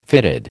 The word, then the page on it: fitted: American English